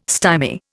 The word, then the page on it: stymie: Indian